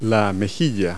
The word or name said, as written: cheek